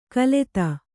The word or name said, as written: ಖಲತಿ